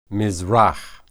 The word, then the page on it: mizrah: hebrew